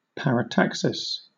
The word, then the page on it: parataxis: British English